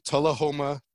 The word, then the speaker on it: Tullahoma: You are originally from Tullahoma, Tennessee. Did I say that correctly? – You did. Yeah. – All right. – You got it.